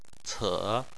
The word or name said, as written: ce3